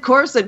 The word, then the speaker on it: Coruscant: And then when they said Coruscant, people were like, oh, that's how you pronounce it. It's not Coruscant.